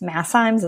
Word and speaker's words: MassZymes: It's spelled Massienzymes. Masszymes is how you say it.